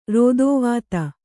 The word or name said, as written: ರೋದೋವಾತ